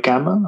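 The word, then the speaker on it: Gama: That would sell a book called Combat Conditioning, and it was all based on Hindu push-ups and Hindu squats from the Great Gamma. Am I saying that right? – That's correct, yeah.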